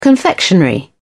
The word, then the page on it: confectionery: British English